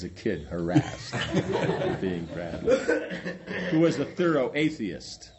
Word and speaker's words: Bradlaugh: laughs> The other kind was taught by Bradlaugh. Like I said, they got to work on their names here. – It's pronounced Bradlaugh.